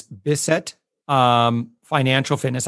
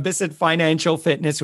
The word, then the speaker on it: Bissett Financial Fitness: So that website is Bissett Financial Fitness. I don't know if I said that correctly. – You did. So, okay, good. Bissett Financial Fitness.